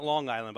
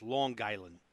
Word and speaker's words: Long Island: It's not Long Island, by the way, Herb. It's Long Island. Long Island. That's how they say it up there.